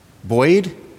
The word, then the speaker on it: buoyed: What is the word B-U-O-Y-E-D, guys? How would I pronounce that? Buoyed? Buoyed? Okay.